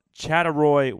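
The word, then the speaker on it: Chattaroy: Now, I know that you are a native of Chatteroy, Washington. Is that how you say it? – Yep, that's how you say it.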